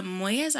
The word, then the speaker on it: Moyes: And it was also with Prisca Moyes. I don't know if I pronounced it. – It's M-O-Y-E-S, right? – Yes, yeah, so close. I guess I might have said that right.